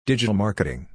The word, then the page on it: Digital Marketing: English